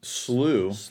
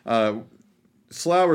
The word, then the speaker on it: Slough: So, Americans apparently say it, Slough. The Slough of Despond, where the Brits say the Slough of Despond, which is what I've been calling it.